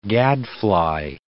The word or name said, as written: gadfly